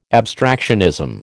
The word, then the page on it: abstractionism: American English